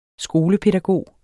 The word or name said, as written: skolepædagog